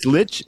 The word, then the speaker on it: Leitch: David Lynch directed Deadpool 2, and then he directed Bullet Train. – Is it Lynch or? – Lynch. I think it's Lynch, no? – It's Lynch. It's L-E-I-T-C-H.